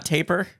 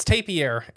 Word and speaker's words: tapir: It's not tapir, not taper? – It's tapir is how you actually pronounce it. Huh. I even say taper. – I always thought taper. – But I'm an American, and I make every word around the world an American word. – As they should be. – No, it's a tapir. – Okay. – That is the correct pronunciation.